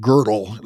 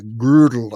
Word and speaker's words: Gödel: I think that Alan Turing later built on some of the ideas of Kurt Gödel, And by the way, somebody said his last name is not Girdle, you know, like the undergarment of a woman trying to look thin. So I don't know how to say it. Girdle, I believe, is the proper way of doing it.